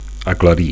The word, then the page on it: aclarir: Catalan